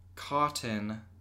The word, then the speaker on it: KOTN: All right, so it is called Cotton, and that's spelled K-O-T-N.